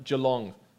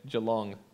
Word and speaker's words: Geelong: Geelong, that's how they say it, Geelong, Victoria.